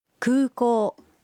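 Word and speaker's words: Kūkō (空港): Kūkō made onegai shimasu. – Al aeropuerto, por favor. – Kūkō es aeropuerto. Los sonidos U y O en Kūkō se pronuncian alargados.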